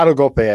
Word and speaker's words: autogolpe: I think I'm saying that right. That's Spanish. Mm-hmm. Can you help me out there? Is that autogulp? – I don't speak Spanish either, but yeah, autogolpe, I think.